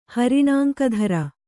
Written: ಹರಿಣಾಂಕಧರ

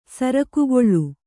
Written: ಸರಕುಗೊಳ್ಳು

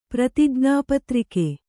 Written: ಪ್ರತಿಜ್ಞಾಪತ್ರಿಕೆ

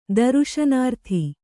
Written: ದರುಶನಾರ್ಥಿ

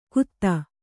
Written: ಖುದಾ

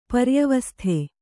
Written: ಪರ್ಯವಸ್ಥೆ

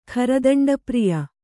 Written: ಖರದಂಡಪ್ರಿಯ

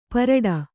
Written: ಪಾರಟ

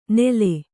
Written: ನೇಲೆ

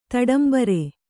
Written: ತಡಂಬರ್

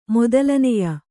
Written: ಮೊದಲನೆಯ

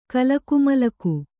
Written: ಕಲಕುಮಲಕು

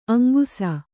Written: ಅಂಕಿಸು

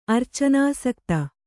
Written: ಅರ್ಚನಾಸಕ್ತ

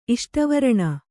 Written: ಇಷ್ಟವರಣ

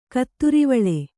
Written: ಕತ್ತರಿವಾಳೆ

